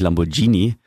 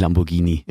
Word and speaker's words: Lamborghini: Erstmal finde ich es ja schön, dass du Lamborghini ausgesprochen hast und nicht Lamborghini. Ja. Das heißt ja wirklich Lamborghini.